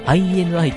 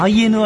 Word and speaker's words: INI: 月 3 日にメジャーデビューを果たした、はい、うん、イニかな ?INI。イニイニ初めて聞いたわ。イニじゃないよね。 IRI だったら知ってるんだけど、そっちは違うよね、多分。いやー、 INI。 INI か。あ、 I、ん ?INI って読むみたい。あ、 INI って読むんだ。うん。 NTT みたいなこと。